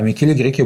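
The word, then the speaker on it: Michele Greco: а Микеле Греко был глава капитула.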